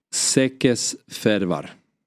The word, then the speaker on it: Székesfehérvár: Sekes Fervar. Ja. – Jag tror det är ganska bra uttalat.